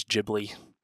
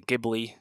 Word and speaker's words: Ghibli: Also, I'm saying it as Ghibli. Some people say Ghibli. If you're Japanese, you say Ghibli. Hayao has talked about the pronunciation, where it came from. He actually took, I believe, an Italian word and pronounced it incorrectly. There's a whole story about that. People say it differently. You know what I mean. I'm going to keep saying Ghibli. That's how I always understood it, and I believe that's the generally accepted English pronunciation.